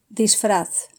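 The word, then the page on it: disfraz: Spain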